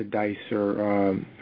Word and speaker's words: Dicer: D-I-C-E-R. – D-I-C-E-R.